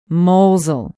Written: mosel

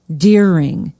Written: doering

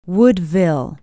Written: woodville

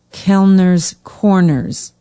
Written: kellners corners

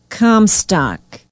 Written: Comstock

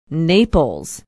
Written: naples

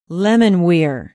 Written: lemonweir

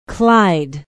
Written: Clyde

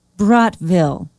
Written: brodtville

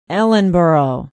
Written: ellenboro

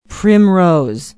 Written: primrose

